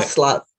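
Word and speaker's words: Václav: That reminds me of the quote that I think you have on your signature, maybe it was in the article, Vaclav Havel, am I pronouncing that correctly? Can you talk about that? – Vaclav, yeah.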